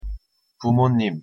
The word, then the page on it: 부모님: Korean